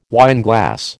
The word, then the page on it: wineglass: American English